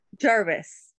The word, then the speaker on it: Jarvis: Jarvis.